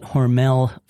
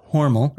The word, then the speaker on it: Hormel: You might call it Hormel Food Company, but the correct pronunciation is Hormel.